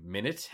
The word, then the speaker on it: Minit: It is spelled MINIT.